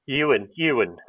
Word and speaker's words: Euan: But I wanted to send out a shout out to, I think you say it, Ewan, E-U-A-N. You would think that's quite an Irish name, is it not? E-U-A-N. You say that Ewan or Owen, do you reckon? – Ewan. We've called him Owen to make it easier a lot of the time. So I think it's actually pronounced Ewan or Owen. It depends which twang of accent you put to it, Aaron.